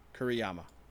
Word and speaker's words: Kuriyama: Kuriyama.